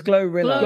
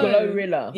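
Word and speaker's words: GloRilla: It's Glow Rilla. – Glow Rilla. – Yeah. – I actually thought it was like pronounced like Gorilla. – I thought that's what she wanted. – Yeah. Like, no. – No? Okay. No.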